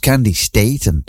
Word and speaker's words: Candi Staton: And then again, not so long ago, she said, oh, it's pronounced Candy Staten.